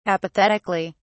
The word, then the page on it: apathetically: British English